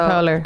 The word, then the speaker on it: Poehler: Amy po- Poehler.